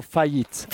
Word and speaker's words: faillite: So you've got échec, an échec, like the chess game, échec, or faillite.